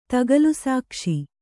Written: ತಗಲುಸಾಕ್ಷಿ

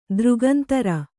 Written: ದೃಗಂತರ

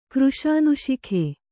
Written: ಕೃಶಾನುಶಿಖೆ

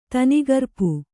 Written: ತನಿಗರ್ಪು

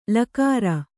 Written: ಳಕಾರ

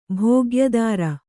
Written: ಭೋಗ್ಯದಾರ

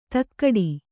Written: ಧಕ್ಕಡಿ

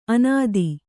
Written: ಅನತಿ

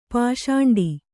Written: ಪಿಸಾಂಟ

